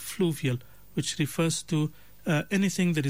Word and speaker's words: fluvial: Well, the word that we were looking for, as I said, alhamdulillah, 3 correct answers today, is the word fluvial. Fluvial. It's spelled F-L-U-V-I-A-L. Fluvial refers to the processes or the landforms, anything associated with the flowing of a river or a stream.